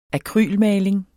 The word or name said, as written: akrylmaling